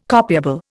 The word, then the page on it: copiable: Indian